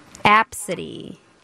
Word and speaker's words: ABCDE: Her first name is ABCDE. – Absidy. – That's how they pronounced it. Absidy.